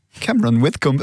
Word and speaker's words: Cameron Whitcomb: Cameron Whitcomb, oui, j'essaye de le prononcer avec l'accent anglais comme il faut.